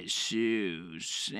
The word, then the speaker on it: Jesus: Ladies and gentlemen, welcome to episode 29 of Sports with Jesus and his sports.